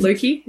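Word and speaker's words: loci: Loki, is that? – Yeah, that's right. Yep. Yeah. – Loki.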